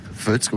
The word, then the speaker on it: Foetz: On prononce bien Futz comme ça Futz, comme Luc, c'est ah. pareil que ça, le même orthographe qu'au Luxembourg, la ville. Ah. F O E T Z.